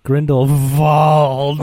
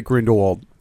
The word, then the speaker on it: Grindelwald: I heard all the other people say Grindelwald, and then I heard Dumbledore be like, Grindelwald. – Only the muggles pronounce it Grindelwald. You, if you're a true magic person, you say it with a V.